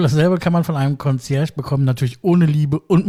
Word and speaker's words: Concierge: Ich denke mal, es hat keiner von euch zu Hause einen Concierge-Service, so wird es ausgesprochen. Es sei denn, er wohnt noch bei Mutti, dann ist Mutti sowas wie Concierge.